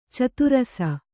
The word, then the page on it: ಚದುರಸ: cadurasa